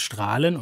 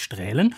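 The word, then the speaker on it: Straelen: Ich sage nur den Ort Strahlen, der sich mit AE schreibt, was man auch für ein Ä halten könnte. Aber er heißt Strahlen und nicht Strählen.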